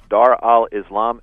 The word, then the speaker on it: Dar al-Islam: In Arabic, it's pronounced Dar al-Islam and Dar al-Harb.